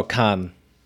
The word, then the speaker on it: Cannes: Is it pronounced Con? Cannes, France? – Oh, Cannes.